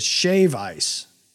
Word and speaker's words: shave ice: I think you said it wrong the first time. It is shave ice.